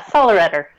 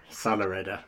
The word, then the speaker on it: Sollereder: Have I said that right? – Yeah, Sollereder. – Sollereder, you say it better.